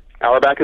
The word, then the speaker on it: Auerbach: I'm pronouncing your name right. Auerbach? – Auerbach is right.